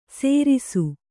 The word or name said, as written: ಸೇರಿಸು